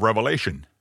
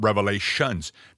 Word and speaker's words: Revelation: It's the book of Revelation. Not Revelations.